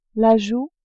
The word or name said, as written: joue